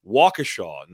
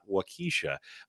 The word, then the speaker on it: Waukesha: It is Waukesha, not Waukesha. If I've heard it one time mispronounced, I've heard it mispronounced dozens of times.